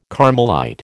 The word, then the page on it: Carmelite: American English